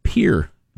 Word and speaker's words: Pierre: Capital of South Dakota, Pierre. – There you go. – That's how you say it, fools.